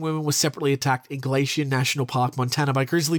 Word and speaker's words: Glacier: Of course, the attack occurred on the same night, August 13, 1967, also by a wild brown bear at Glacial National Park. Glacier National Park.